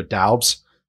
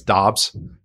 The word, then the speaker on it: Doubs: I always want to say Romeo Doubs, but apparently it's Dobbs, how you pronounce it. I've heard three different pronunciations for it. So I'm just I think it is Dobbs. They threw the U in there just to confuse everybody.